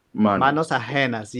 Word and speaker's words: Manos Ajenas: The Menos Jesus. – Manos Ajenas. Yes, it's in Spanish. Manos, and you produced it.